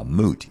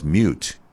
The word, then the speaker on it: moot: Now again, that is a term of law, moot. It's M-O-O-T. If you hear anybody mispronounce it as mute, you need to castigate them severely, metaphorically speaking.